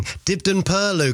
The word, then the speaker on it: Dibden Purlieu: Vanda's got in touch saying, hi Joel, congratulations for pronouncing Dibden Purlieu correctly.